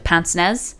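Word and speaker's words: pince-nez: I know it's a weird thing to start a Mother Knows Best with, with Pince Nez.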